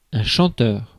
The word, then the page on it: chanteur: France; /ʃɑ̃.tœʁ/